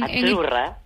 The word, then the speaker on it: Azzurra: La Z no es la Z como decimos en catalán tan sonora, no. Ah, es azurra.